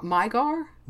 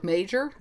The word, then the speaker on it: Magyar: No, apparently the G-Y sound, because I always thought like Magyar or Magyar, but the G-Y sound is an individual letter of Hungarian alphabet, and it's pronounced like we do a soft J sound.